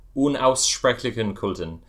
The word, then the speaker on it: Unaussprechlichen Kulten: Is it Unaussprechlichen Kulten? Am I pronouncing that right? – Unaussprechlichen Kulten.